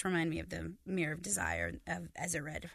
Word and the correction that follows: Erised: Erised. – Erised, thank you.